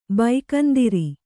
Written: ಭಗಂದರ

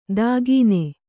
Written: ದಾಗೀನೆ

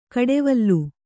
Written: ಕುಡಿವಲೆ

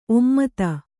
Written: ಒಮ್ಮೊತ್ತ

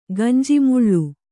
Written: ಗಂಜಿಮುಳ್ಳು

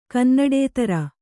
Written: ಕನ್ನಡೇತರ

